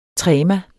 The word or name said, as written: trema